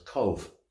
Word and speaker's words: Cobh: So we moved them to a town called Cove, spelled, you won't believe this, C-O-B-H, but it's pronounced C-O-V-E. It's the Gaelic spelling. So the B-H sound in Gaelic makes the V sound in English. – Oh, okay. – So it's Cove, which was, ironically, the last stop of the Titanic before she hit the iceberg.